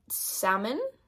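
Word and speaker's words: Salomon: So I don't want to buy it yet, but I have seen the brand Salmon, Salmon, so I don't know. I feel like Americans make things that aren't their name sound different, but I think it's pronounced salmon like the fish.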